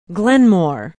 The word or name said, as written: Glenmore